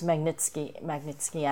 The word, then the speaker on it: Magnitsky: It's Magnitsky Act, and well, the story is that his law firm had this accountant, Sergei Magnitsky.